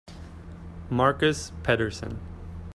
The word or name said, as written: Pedersen